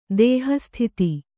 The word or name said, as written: ದೇಹಸ್ಥಿತಿ